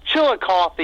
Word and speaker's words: Chillicothe: Are we saying that right? Chillicothe or Chillicothe? – It's Chillicothe, yeah. I mean, you can kind of, I guess, say it a couple different ways.